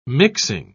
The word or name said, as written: mixing